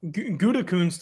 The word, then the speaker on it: Gutekunst: I guess I don't know about how do you say the Packers GM's last name? – Gutekunst. – Gutekunst. yeah, I've been to Germany.